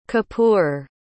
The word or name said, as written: kippur